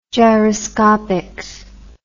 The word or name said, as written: gyroscopics